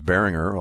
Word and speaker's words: Berringer: And so I knew it was Berringer all the way.